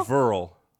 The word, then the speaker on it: Verrill: Is it pronounced Verrill? – Verrill? Verrill? – Verrill. That's how it's pronounced.